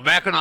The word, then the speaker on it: Mackinac: Mackinac Island. – Mackinac Island. – First of all, yeah. – It's spelled, why do they spell it Mackinac when it's Mackinac?